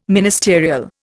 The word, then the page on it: ministerial: Indian